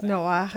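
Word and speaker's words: Noah (נח): En hebreo se pronuncia Noah como Bach. Es Noah, es como se debe pronunciar.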